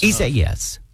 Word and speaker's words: Isaias: Hurricane Isaias, I think, is how you properly pronounce that. Let's check with Metro News meteorologist. Am I saying it right? Close enough? – Yes. Yeah, close enough. Isaias. Yes. – Right, that is correct. Isaias. Yeah.